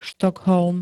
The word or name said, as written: Štokholm